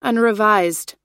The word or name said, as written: unrevised